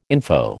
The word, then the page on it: info: American English